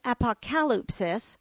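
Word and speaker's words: apokalypsis (ἀποκάλυψις): Although the noun is pronounced apokalypsis, the verb is pronounced apokalypto.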